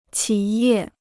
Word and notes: 企业: qǐ yè

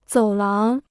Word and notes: 走廊: zǒu láng

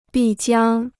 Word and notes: 必将: bì jiāng

